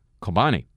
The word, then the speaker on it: Kobani: is diverting most of our airstrikes to the town in Syria on the Syrian-Turkish border, Kobani, which I mispronounced last week as Cobain because I thought the Syrians named it after a dead rock star.